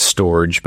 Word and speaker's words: Storj: It's S-T-O-R-J. Some people pronounce it storage, but we say storage so many times that it gets confusing. So StorJ. So we have an integration with StorJ that allows either to back up or disaster recovery to their cloud.